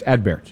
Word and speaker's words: Adbert: the T is pronounced, but it is not pronounced powerfully. You don't say, it's not Adbert or Adbert. It's Adbert. – You kind of brush it.